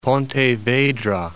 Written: Pontevedra

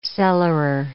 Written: cellarer

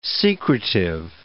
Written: secretive

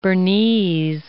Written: Bernese

